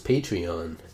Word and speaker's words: Patreon: I was just been calling it Patron. But I guess it is Patreon. – It's Patreon, and you become a patron by using Patreon.